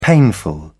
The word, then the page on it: painful: British English